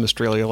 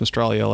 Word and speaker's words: Mistralia: It's pronounced Mistralia. – Okay. Was I not close? – Nope. You said Mistralia like Australia. It's Mistralia like Jambalia. – Like Australia? – Jambalia. You know, like the Cajun gumbo.